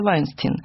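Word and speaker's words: Weinstein: И основным продюсером должны были выступать братья Вайнстин. Угу. Собственно говоря, как эта фамилия правильно произносится. В Америке.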